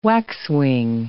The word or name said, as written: waxwing